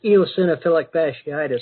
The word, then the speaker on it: eosinophilic fasciitis: And that's eosinophilic fasciitis is how it's pronounced.